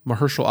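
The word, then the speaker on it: Mahershala: Yeah, and apparently the guy playing Blade, is it Herschel or Mahershal? – Mahershal Ali.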